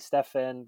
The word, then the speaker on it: Stephen: Pauline mentioned I'm Stephen. I live here in France. The French call me Stefan. The Filipinos call me Stephen. The name is pronounced however it is, wherever it is in the world.